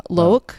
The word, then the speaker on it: Loick: Loke. Oh. Is his... – Is it like spelled like lock, but loke? – L-O-I-C-K.